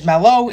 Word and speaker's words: Mailloux: If you guys haven't been keeping up with the NHL, Logan, I believe I'm going to pronounce his name as Mello. It is spelled M-A-I-L-L-O-U-X.